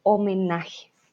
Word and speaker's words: homenaje: El homenaje. Y recuerden, la H aquí tampoco se pronuncia, no decimos homenaje. La H es muda, como si no estuviera en este caso.